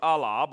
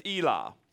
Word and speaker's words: Elah: You said the way it's pronounced in the Greek, you would think it's Allah, but it's Elah. That's how you say A-Y, Elah.